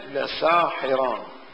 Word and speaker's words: لساحران: وإن هذان لساحران، بينما في الرسم القرآني إن هذا لسحران، هذان الرسم يعني الرسم لسحران، بينما هي لساحران، يعني ألفين ما موجودتين في الكلمة.